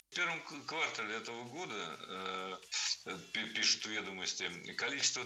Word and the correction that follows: квартал: Не квартал, а квартал. Причем такое ударение актуально при любом значении слова. И часть улицы, и часть года. Всегда в слове «квартал» ударяем второй слог.